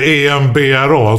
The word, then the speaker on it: Edinburgh: Vi åker ju ibland till eh, Comedy Festival i Edinburgh. – Mm. – Eller EMBRA som man ska uttala. det. Embra. Embra! Welcome to Embra!